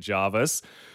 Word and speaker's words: Jervis: Your auntie texted you moments after that saying, can confirm it's Jarvis, 100% Jarvis.